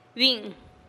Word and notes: bi'in: TdVZ

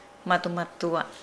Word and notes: madumadua: Nukuoro

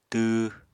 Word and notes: tw: apatani

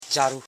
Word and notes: charu: cha.ru

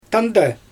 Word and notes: tandaj: Xyzyl

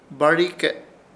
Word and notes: BAHRIKE: Ishir Chamacoco

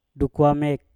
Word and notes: dukwamek: Mpur

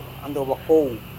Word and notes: andowa-kou: Serewen

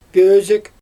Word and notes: pöözük: Xyzyl

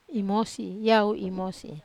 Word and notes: mosi: Wamesa

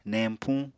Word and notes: neñpuñ: Apatani

